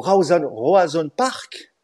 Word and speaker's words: Roazhon Park: Roazhon Park que je l'ai bien prononcé, euh, à 20h45.